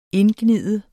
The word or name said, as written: indgnide